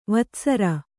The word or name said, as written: ವತ್ಸರ